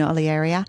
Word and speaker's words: Olearia: I have some stunning little smoky olearia. Is that how you pronounce it? – Olearia. Yeah, that's okay. Depends what school you went to, but I don't mind.